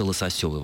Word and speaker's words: лососёвый: И, соответственно, мы могли сказать лососевый и лососевый. Да, но вот словари, насколько я увидел в словаре русского языка и ударений Ирины Леонидовны Резниченко, только лососёвый, возможно, форма.